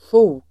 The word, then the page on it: sjok: [sjo:k]